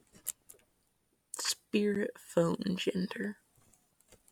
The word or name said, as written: spiritphonegender